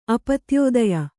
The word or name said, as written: ಅಪತ್ಯೋದಯ